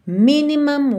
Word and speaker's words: minimum: O cuidado que você tem que ter é só para esse u não ficar muito u, não é mum, mum é mam, mam, ok? – Então Minimum Waste. E essa sílaba que tem o M e o M é a última sílaba. E as sílabas tônicas são as primeiras nessas duas palavras. Então você tem minimum e maximum, ok? Minimum, maximum.